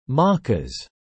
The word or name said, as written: Maccas